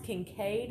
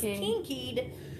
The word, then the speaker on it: Kinkead: K-I-N-K-E-A-D. So it's pronounced Kincaid, I believe. But I thought it was Kinkied.